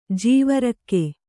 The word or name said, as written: ಜೀವರಕ್ಕೆ